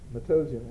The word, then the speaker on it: Matoesian: It's A period Andreas, A-N-D-R-E-A-S, Matossian, M-A-T-O-E-S-I-A-N.